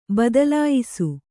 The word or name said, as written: ಬದಲಾಯಿಸು